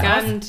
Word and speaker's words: Gant: Gant, Gent, wie spricht man? – Gant, ja. Gant.